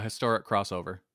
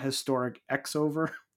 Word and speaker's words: Historic X-Over: So I'm like it is historic crossover, right? Not historic X over.